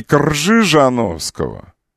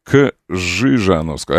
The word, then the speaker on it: Кжижановского: РЖ. Пишется буквенное сочетание. Читается как Ж. И таким образом, не Кржижановского. Улица Кржижановского. Должна быть Кжижановского.